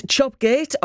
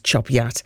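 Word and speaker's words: Chop Gate: Andy in Redcar says, okay, is it Chopgate or Chopyat? I thought it's, for me, it's spelt Chopgate, but it's pronounced Chopyat.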